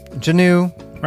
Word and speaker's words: Janu: We got a letter from Janu. – Rhymes with canoe.